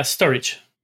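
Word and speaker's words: Storj: Yeah, storage.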